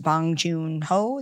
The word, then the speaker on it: Bong Joon-ho: And I think that Bong Joon-ho, did I say that right? – You did.